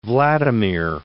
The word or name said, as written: Vladimir I